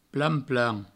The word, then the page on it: plan-plan: Provençal